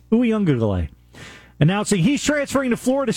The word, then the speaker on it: Uiagalelei: DJ Uiagalelei. – Oh, there's an extra G in there. Uiagalelei. Say it again. – DJ Uiagalelei. There's an extra syllable in there that you got to get.